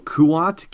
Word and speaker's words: Kuat: Kuat, K-U-A-T, the Kuat Drive Yards, where there was a ton of Imperial manufacturing.